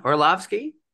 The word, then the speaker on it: Orlovsky: Orlovsky.